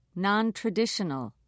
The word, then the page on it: nontraditional: British English